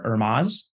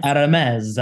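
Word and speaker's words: Hermès: And they said, Domino Sugar Factory is hosting an event for Hermes. Is that how you pronounce it? – Well, it depends if you want to do a very heavy French accent on it, but yes. – Hermes.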